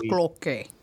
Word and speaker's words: Cloquet: It's pronounced Cloquet. – Cloquet.